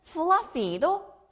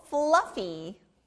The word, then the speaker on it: fluffy: 아, 털이 많은 fluffy도 F 소리가 나네요. 우리가 강아지나 곰인형 보면 털이 아주 많고 부드럽죠? 털이 많은, 영어로 fluffy. Fluffy. Fluffy. 발음이 너무 좋아요.